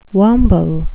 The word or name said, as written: wamble